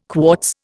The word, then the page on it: quartz: Indian